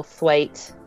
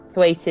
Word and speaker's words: Thwaite: Thwaite. Thwaite is a Yorkshire name that not many people know how to spell.